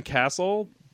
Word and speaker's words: Cassil: No, my plug, you can follow me on Twitter at Ben Castle, C-A-S-S-I-L is how you spell that last name.